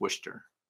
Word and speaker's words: Worcester: Worcester, Massachusetts. I hope I said that right. It's spelled Worcester, but I think it's Worcester. That's the way they say it.